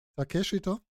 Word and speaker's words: Takeshita: Also wirklich, ich glaube, dass das Highlight war eigentlich wirklich noch, sagst schon, wie hast du ihn ausgesprochen? Takeshita? Du kannst es besser als ich. – Das ist ein stummes I.